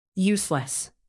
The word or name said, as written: useless